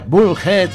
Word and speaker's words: Bullhead: ¿Es Bullhead? Bullhead, Arizona. Así se pronuncia, Bullhead, Arizona.